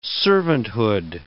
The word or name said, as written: servanthood